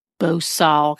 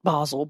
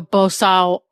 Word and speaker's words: bosal: So if the horse is trotting around with nothing in his mouth, he's going to have a bosal, because I pronounce it Basel, but I'm pretty sure that's not the way you're supposed to say it. He would have a bosal of some type on.